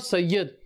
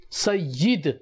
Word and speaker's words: Sayyid: So how, what is the proper pronunciation of Sayyid? It's not Sayyid. Sayyid.